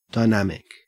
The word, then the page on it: dynamic: Australian English